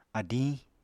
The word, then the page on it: adiñ: Apatani